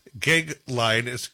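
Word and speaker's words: Goeglein: It's Timothy, and his last name's Gegline, and it's spelled G-O-E-G-L-E-I-N-E.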